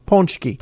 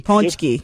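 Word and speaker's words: pączki: If you look at the way the word is spelled, it's P-A-C-Z-K-I. – Mm-hmm. – The A has a little tail hanging on the bottom of it. – Okay. – That A sounds like if you would say the word om. – Okay. – Okay. It's not, you don't pronounce the N as much, but it's ponchki, see? – And the singular again?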